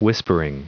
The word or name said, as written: whispering